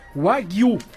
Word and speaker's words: Wagyu: Mas, ó, o jornal falando a pronúncia da palavra é Wagyu.